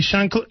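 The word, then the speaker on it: Honkala: You heard Sherry Shankla, who is the vice presidential candidate for the Green Party. Did I mispronounce her name? Go ahead. Break it down for me, man. – Shankla. – There you go.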